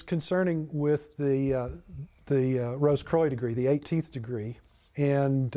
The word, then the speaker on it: Rose-Croix: I won't go into too much detail on them, but my next book is really focused on the Rose-Croix degree, or more properly pronounced Rose-Croix, but most Americans say Rose-Croix. – Right, like LaCroix.